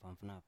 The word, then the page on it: fafnaf: Biak